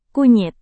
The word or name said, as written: kunyit